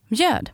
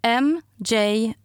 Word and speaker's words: mjöd: And the word is mjöd. Mjöd means mead, and it's spelled M-J-Ö, which is an O with two dots on top, D. So M-J-Ö-D, mjöd.